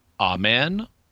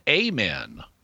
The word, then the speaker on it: Amen: In English, it is commonly said Amen with the emphasis on the second syllable, but sometimes it is said Amen with the long vowel sound and the emphasis on the first syllable.